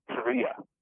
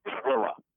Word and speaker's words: Perea: I guess he was Perea, not Parrilla. – Okay. – Perea was how you pronounced it in his language you know,